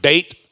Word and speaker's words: Bethel: It's no longer Luz anymore, L-U-Z. He names it Bethel. Beth in Hebrew, it's pronounced Beit, but Beth, as we would say it, means house.